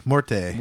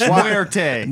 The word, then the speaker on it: Muerte: Das Morte. – Muerte. – I can't ever say it right. – Spanish. Muerte. – Muerte.